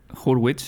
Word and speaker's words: Hurwitz: La música está compuesta por Justin Hurtwitz. Eh, creo que es Hurwitz, sí. – Hurwitz, Hartwitz o, Justin, Bueno, se escribe H-U-R-W-I-T-Z. Justin Hurwitz, por así decirlo.